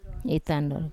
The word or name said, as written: itandor